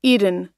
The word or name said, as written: eden